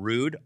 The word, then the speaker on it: Rhude: Rugier Villasenor, who is the creative fashion director for the Coyotes, and he has also started the fashion brand Rude, R-H-U-D-E.